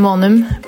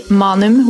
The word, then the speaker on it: Maanum: So in Norwegian, we say Frida Leonhardsen Maanum, but in England, they pronounce it totally wrong and they say Manum, which is wrong, but I mean, I can take it.